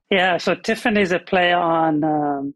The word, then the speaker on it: TIFIN: And so TIFIN is the name of the company.